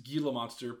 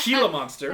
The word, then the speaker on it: Gila Monster: I really love the track, which Chris will tell me is pronounced Gila Monster, but Gila Monster.